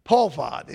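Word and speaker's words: pahad (פחד): Pahad. It's spelled P-A-H-A-D, but the transliteration of it is pahad.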